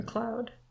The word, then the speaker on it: McLeod: How do you pronounce that? McLeod. You lived in the UK. – Yeah. McLeod.